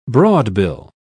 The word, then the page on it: broadbill: British English